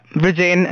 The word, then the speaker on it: Vrujen: Yeah, Vrujen, Andhare.